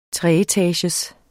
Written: treetagers

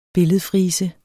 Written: billedfrise

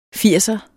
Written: firser

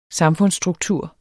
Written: samfundsstruktur

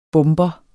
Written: bomber

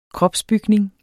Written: kropsbygning

